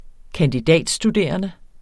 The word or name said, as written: kandidatstuderende